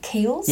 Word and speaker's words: Kiehl's: Well, I don't ever know how to say it correctly. Kiehl's? – Yes. – Okay, I did that properly.